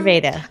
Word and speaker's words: Ayurveda: Ayurveda. – Ayurveda. Your personal Ayurveda system and how you describe the different types of bodies. Can you touch on that? – Sure. So I did not invent Ayurveda.